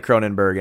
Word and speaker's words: Cronenberg: I'm pretty sure that they pronounce the O-U-R-G as Berg, but I could be out of bounds. – Well, I don't know. – I'm an American. I'm stupid. I'm not taking into account their culture at all like I should be, but it's spelled Cronenborg for me, so that's how I'll... But Cronen, okay. It's probably Cronenberg, and I'm a, you know, stupid American, so...